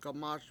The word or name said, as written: gamAS